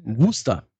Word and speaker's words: Worcester: Die Soße wird Worcestershire-Soße ausgesprochen. Worcester.